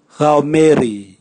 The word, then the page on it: gaomeri: Raga